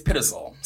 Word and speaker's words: Pitzel: God, I'm gonna say it wrong. Benjamin Pitzel. – Hold on, I'm gonna look it up. Okay, we looked it up. It's Pitzel. So Benjamin Pitzel was his accomplice.